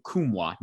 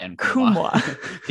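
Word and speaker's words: Nkamhoua: Olivier Kumwa, not Nkumwa. The N is... – the silent the N. Yes.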